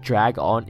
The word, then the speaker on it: dragon: Just remember that drag on is not dragon. Dragon is one word. Drag on is two.